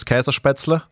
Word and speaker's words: Käsespätzle: The proper pronunciation is Käsespätzle.